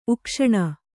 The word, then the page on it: ಉಕ್ಷಣ: ukṣaṇa